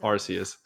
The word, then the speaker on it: Arceus: but well, in terms of English spelling, it should be pronounced Arceus.